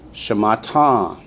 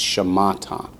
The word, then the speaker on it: shamata: Shamata. – Shamata. – Actually, I accented the last syllable more than I should have. It's shamata.